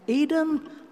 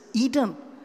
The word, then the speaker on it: Eden: And Eden, well, that's how they pronounce it in Hebrew. In English, we pronounce it as Eden.